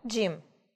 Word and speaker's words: gym: Jim. I'm sorry. Спортзал, да? Gym.